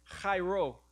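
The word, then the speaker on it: chairo (χαίρω): Because this word in Greek, and I don't know if I can say it, chairo is how you say the word in Greek.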